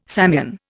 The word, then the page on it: Samian: Indian